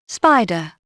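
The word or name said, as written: spider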